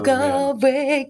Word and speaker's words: Galway: Galway and spent 2 nights there.